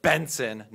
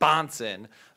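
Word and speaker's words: Bahnsen: And when I talked about Benson, apparently that means any arguments I put out because I said Benson, not Bonson, means I don't know what I'm talking about, as if I've never read Benson before.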